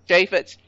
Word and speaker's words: Chaffetz: Oh, Chaffetz, yeah. – Is that how it's pronounced? – Yep. – Chaffetz.